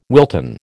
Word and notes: Wilton: American English

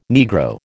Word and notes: Negro: American English

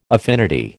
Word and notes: affinity: American English

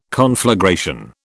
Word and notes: conflagration: British English

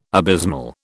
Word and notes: abysmal: British English

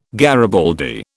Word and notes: garibaldi: British English

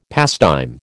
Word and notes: pastime: American English

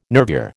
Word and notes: nervure: American English

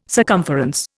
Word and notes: circumference: Indian

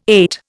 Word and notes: ate: Indian

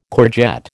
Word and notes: courgette: American English

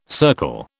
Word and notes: circle: British English

